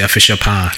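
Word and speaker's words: Afehyia pa: In Ghana, they say Afishapa. – Afishapa. – Did I get that pronunciation right? – Yeah.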